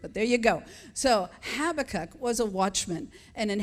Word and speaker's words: Habakkuk: He was a prophet with a burden for God's people, and the pronouncer says that you say it, Habakkuk.